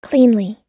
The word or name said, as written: cleanly